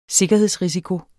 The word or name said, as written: sikkerhedsrisiko